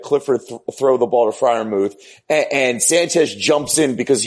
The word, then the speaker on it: Freiermuth: How do you say, 87's name? Freeburg? – It's Pat Freiermuth. Exactly how you said it, David.